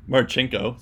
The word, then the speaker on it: Marchenko: Um, and then so the last guy we're going to talk about is Kirill Marchinkov, Marchenko, sorry, another hard to pronounce last name here.